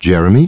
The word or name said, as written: Jeremy